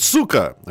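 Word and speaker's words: suka (сука): It's not chika blat, it's suka. Suka blat. Suka.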